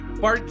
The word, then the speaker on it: Bart: Bart Sibrel, not Bob Sibrel, but close enough.